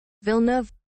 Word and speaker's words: Villeneuve: How do you think you pronounce that? – I have no idea. – Villeneuve. – Denis Villeneuve. All right. – That does not sound like how it looks.